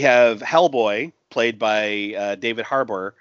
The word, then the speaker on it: Harbour: Am I saying his name right? I always say it wrong. – I just say Haba. – Haba? – Haba. – Haba. – I think it's Harbour. – It's Harbour, yeah.